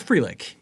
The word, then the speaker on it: Frelick: Sal Frelick, am I saying his name right? – It's Frelick, yes. Frelick.